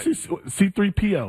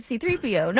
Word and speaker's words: C-3PO: C-3PO, why do you say that? – How are you supposed to say it? – It's like one word, C-3PO. Not C-3PO. Not C-3PO.